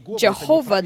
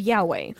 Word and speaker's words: Yahweh: And so they, Jehovah, this is the incorrect pronunciation of Yahweh.